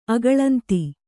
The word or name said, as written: ಅಗಳಂತಿ